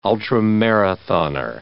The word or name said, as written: ultramarathoner